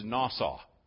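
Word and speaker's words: nasah: But the Hebrew word there is nasaw. And it's pronounced N-A-W-S-A-W. It's N-A-S-A-H, nasaw.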